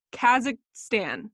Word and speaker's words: Kazakhstan: So it's Kazakhstan.